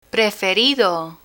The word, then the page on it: preferido: Spanish